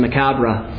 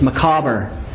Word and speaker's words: macabre: I thought it was macabre, but I have an app and it pronounces it. It's macabre.